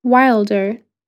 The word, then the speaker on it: Wylder: Anne, spelled A-N-N-E. – And your surname? – Yes, Wilder. – Wilder. – Do you want me to spell that? – Yes, please. – W-Y-L-D-E-R.